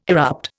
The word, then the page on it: erupt: Indian